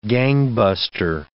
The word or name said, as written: gangbuster